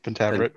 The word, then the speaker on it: Pentaverate: So Mike Myers, six episode Netflix comedy series, The Pentaveret. Am I saying that right? – Yeah, Pentaveret.